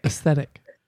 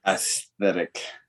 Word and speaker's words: aesthetic: Aesthetic. – Aesthetic. – There's a TH right in the middle of the word.